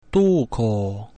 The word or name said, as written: duco